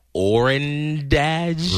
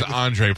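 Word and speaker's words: Ondřej: We have a player on our team whose first name is spelled O-N-D-R-E-J. How do you pronounce that name? – Orandage. – Right. That would be incorrect again. That is Andre Palat.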